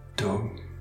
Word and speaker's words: DHUM: Répétez la graine, le mantra TUM, D-H-U-M, le H qui se prononce comme une expiration, le U comme un OU, TUM.